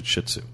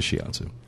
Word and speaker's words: shiatsu: Although there are people who pronounce it shitsu. – Like the dog? – Yeah. I've heard it said a million times. It's not pronounced that way, but we'll hear people call it shitsu massages.